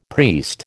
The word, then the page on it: priest: American English